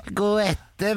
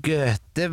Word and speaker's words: Goethe: Han begynte: Goethe var en stor poet. – Vent litt, Karsten, avbrøt lærer Gudrun. Du må huske at O og E sammen uttales Ø.